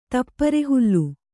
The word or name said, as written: ತಪ್ಪರೆಹುಲ್ಲು